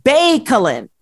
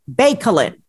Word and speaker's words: Baicalin: It is called Baicalin. Baicalin.